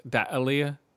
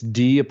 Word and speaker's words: D'Elia: I would probably pronounce it Deli. – It's D'Elia.